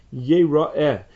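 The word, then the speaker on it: Yeh-ra-eh (יראה): Shenemar, because the Pasuk says, Yireh Yeh-ra-eh, that it's spelled Yireh, but it's pronounced Yeh-ra-eh.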